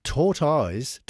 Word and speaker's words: tortoise: And the Welsh side tortoise that right, the Welsh tortoise, the animal, rhymes with turquoise, apparently.